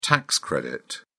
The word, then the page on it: tax credit: British English